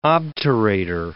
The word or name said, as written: obturator